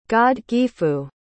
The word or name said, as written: godgifu